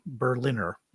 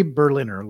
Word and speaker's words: Berliner: I think that's how you say his name. Like the German city Berliner, like someone who lives in there, Yuri Berliner,